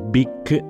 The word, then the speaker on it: Bic: Ora, abbiamo tutti presente che la penna si chiama Bic e non Bic con l'H finale perché in inglese quella H finale avrebbe potuto indurre a una pronuncia sbagliata del nome, troppo simile al termine inglese bitch, che significava e significa tuttora sgualdrina, prostituta.